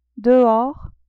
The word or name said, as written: dehors